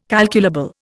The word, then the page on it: calculable: Indian